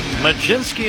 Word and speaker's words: Mlodzinski: Majinski is the way it's pronounced. It is spelled M-L-O-D-Z-I-N-S-K-I.